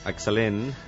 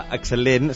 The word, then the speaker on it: excel·lent: excel·lent, s'ha de pronunciar així, no? – Sí, sí, sí. Jo diria que és una... – La tercera, o la segona s'ha d'allargar un pèl, és un excel·lent...